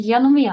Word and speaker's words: Januvia: Janvier. – Mit U. – Okay. Also können Sie noch mal aussprechen? – Okay, Januvia. Ja. – Januvia. – Ja, länger.